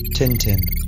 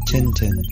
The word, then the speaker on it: Tintin: Computer G, how do you pronounce Tintin in French? – Tintin. Tintin. French version. Tintin.